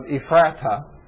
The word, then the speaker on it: Ephrathah: It's kind of in the area there of Ephrathah. Actually, that's pronounced several different ways, Ephrath or Ephrathah.